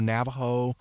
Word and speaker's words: Navaho: And the three that I usually recommend from this work, there's one called Navajo, and it's spelled N-A-V-A-H-O. So Navajo, then Chester, which is going to be very hardy, and then another one called Triple Crown.